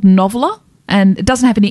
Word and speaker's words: NOVLR: Noveler. Noveler.